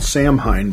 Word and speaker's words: Samhain: It's spelled Samhain, but it's really Samhain. It's a Celtic thing.